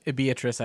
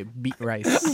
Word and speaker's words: Beatrice: Every time I see the name Beatrice, I just look at it and I say, beat rice. – Beat rice. – I don't know why we pronounce it Beatrice.